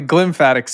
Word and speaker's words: glymphatic: Can you explain, firstly, am I pronouncing that right? And secondly, what the hell it is and how it works? – Yeah, you're talking about the glymphatic system.